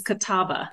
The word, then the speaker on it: Catawba: I think I am, but you actually pronounced it correct. It is Catawba.